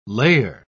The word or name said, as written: layer